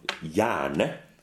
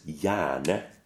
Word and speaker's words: gjerne: And the G is not pronounced. – It's totally silent. – It's totally silent. So you have this word called Jerne. G-J-E-R-N-E. But when it comes out, it says Jerne. So the first E is also A. – Yeah. – Go figure. Really, it's just like that AE sound was. – Yeah. Yeah, precisely. The same sound.